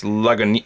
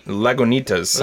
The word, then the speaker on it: Lagunitas: Well, if anybody but we did learn the proper pronunciation of their name, and it's Lagunitas. Lagunitas. I still can't say it.